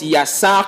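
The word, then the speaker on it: yassar (יצר): Le mot formé ici est un mot hébreu que l'on prononce yassar, qui veut simplement dire en français façonné, ou encore designé, ou encore structuré.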